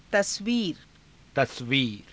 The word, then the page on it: tasviira: Hindi